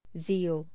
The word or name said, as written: zeal